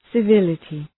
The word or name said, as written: civility